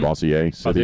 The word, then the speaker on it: Bossier City: And so this gentleman was doing that, and he was in Louisiana, Bossier City. But am I saying that correctly? Mm-hmm. Bossier City. – Bossier.